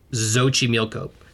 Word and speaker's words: Xochimilco: But in Mexico, the X is actually pronounced as a SH. So like the place where the axolotl or the axolotl are living is a place called Xochimilco, but it's spelled X-O, like Xochimilco.